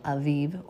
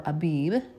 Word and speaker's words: Aviv: The Bible, we already read that in Exodus, that our year is to begin in the month called Aviv or Abib, depending on how you pronounce that letter.